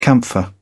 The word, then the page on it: camphor: British English